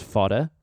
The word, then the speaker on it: Fodr: F-O-D-R, but it's pronounced Fodder.